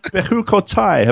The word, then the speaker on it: Bechukotai: Okay, so today we are in Bechukotai. Have I pronounced that right, Nehemiah? – You sure did.